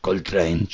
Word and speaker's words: Coltrane: Hay varios temas emblemáticos de jazz que lo nombran al tren, por ejemplo, Coltrane, John Coltrane, valga la redundancia, porque train lo llevan el nombre. – Claro, lo llevan el nombre. Claro, se escribe distinto, pero se suena igual, ¿no?